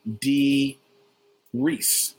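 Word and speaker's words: Dee Rees: The name of the director is actually Dee Reese, like Reese Witherspoon.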